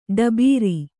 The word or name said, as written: ಟಬರು